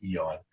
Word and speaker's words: aeon: The funny thing is I learned not too long ago that Aeon is actually pronounced Eon.